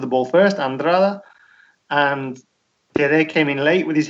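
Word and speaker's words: Dedê: The main talking point was the sending off of the Cruzeiro centre-back Dede, or there is a Brazilian pronunciation of that, isn't there, Austin? I'm not quite sure how you pronounce it. – No, you got it. Dede. – Okay. I thought it was more of like a Gigi or something like that.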